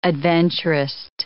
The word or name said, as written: adventurist